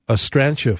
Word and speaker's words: astrantia: Texter says, I love astrantia flowers. Did I say that right? – Yep, you did.